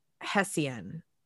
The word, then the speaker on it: Hessian: He was a Hessian. – Okay. – That's how it's pronounced.